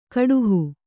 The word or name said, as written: ಕಡುಹು